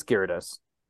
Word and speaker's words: Gyarados: Gyarados. I refuse to say Gyarados. – It that way. No, it's Gyarados.